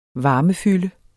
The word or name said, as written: varmefylde